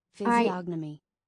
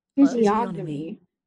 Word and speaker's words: physiognomy: Physiognomy. In British what? English. – Physiognomy.